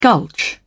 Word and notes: gulch: British English

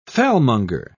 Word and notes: fellmonger: British English

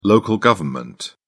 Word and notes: local government: British English

